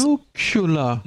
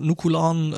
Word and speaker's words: nuclear: Nuclear. Nuclear. – It's pronounced nuclear. Genau, und ähm, Teil eben äh, dieser nukularen äh, Flotte ähm, ist die INS e äh, Arihant.